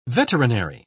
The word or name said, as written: veterinary